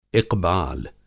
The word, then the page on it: इक़बाल: اِقبال